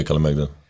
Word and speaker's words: Kalemegdan: and one thing that stuck in my mind, and I know I'm going to butcher the name, is the Kalemegdan. – Yeah, Kalemegdan Fortress, and yeah.